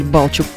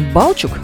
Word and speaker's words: Балчук: Примечательно, что исторически ударение ставилось на оба слога, то есть можно было произнести и «балчук», как мы делаем это сейчас, и «балчук».